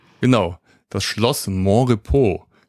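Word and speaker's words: Monrepos: Ich hatte mich kurz gefragt, wie es ausgesprochen wird, aber ich hatte so die Vermutung, dass irgendwie Französisch ausgesprochen wird, ne? – Ja, wird es auch. – Ja, genau, Mont-Repos. Deswegen Montrepos, nicht Montrepos.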